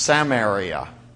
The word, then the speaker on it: Samaria: Really, it's pronounced Samaria.